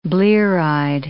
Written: blear-eyed

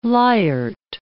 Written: lyart

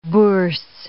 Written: bourse

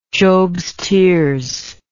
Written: jobs tears

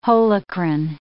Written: holocrine

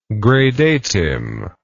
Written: gradatim

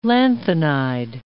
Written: lanthanide

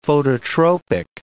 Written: phototropic

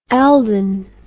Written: elding

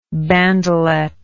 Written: bandelet

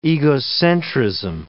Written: egocentrism

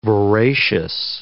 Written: voracious

